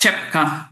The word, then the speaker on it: Rzepka: Genau, also mein Spiel ist Rzepka. T-S-C-H wird das gesprochen.